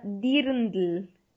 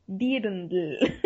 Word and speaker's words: Dirndl: en alemán se llama Dirndl. Dirndl. o sea, así se escribe, así como, como se escucha, se escribe.